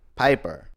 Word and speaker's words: Piper: Rowdy Roddy Popper. Robert, it's really hard to say his name. Rowdy Roddy Piper.